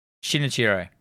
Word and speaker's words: Shinichiro Ueda: Did I say that right? Shinichiro. Shinichiro. Yeah, Ueda. Yeah.